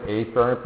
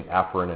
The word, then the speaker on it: afferent: Yeah, we don't usually pronounce it afferent, but I do in lectures so that you could tell the difference between afferent and efferent. The way you really pronounce it is afferent and efferent, which sometimes is hard to understand.